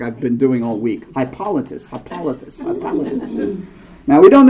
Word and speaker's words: Hippolytus: See, I want to call him Hippolytus, but it's not how you pronounce it. – Hippolytus.